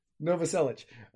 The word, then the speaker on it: Novoselic: Novoselic.